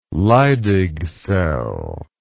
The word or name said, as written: leydig cell